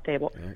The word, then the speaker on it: Daboll: Daboll. Okay, I'm saying it wrong, Dable.